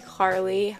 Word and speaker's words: Carlie: And Carlie, my name, is spelled C A R L I E.